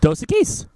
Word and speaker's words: Dos Equis: Dos Equis.